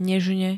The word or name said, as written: nežne